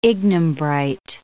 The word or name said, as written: ignimbrite